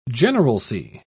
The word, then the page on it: generalcy: British English